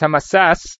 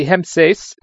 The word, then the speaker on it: Hemses: I pronounced it in the Mishnah as Hamasas. He mentioned to me that he thought the pronunciation should be Hemses, question of whether the hay belongs to the core of the word or does not.